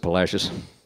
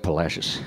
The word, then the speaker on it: Palacios: Town on the Texas coast, which I think at one time may have been called Palacios, but the modern day Texans pronounce it Palacios. Only the Virginians can outdo us for mispronouncing Spanish. They got a town over there they call Buena Vista. Come on, people. Now, California's got some good ones. They got San Rafael, Vallejo, Paso Robles. We come in, we're right up there with them. We got San Jacinto, San Felipe, and we got Palacios, which just happens to kind of rhyme with fascist.